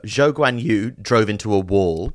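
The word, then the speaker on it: Zhou Guanyu: No, it's it's the so his name is spelled uh, that way, but Chinese pronunciation is Zhou Guan Yu, so it's the other way other way around.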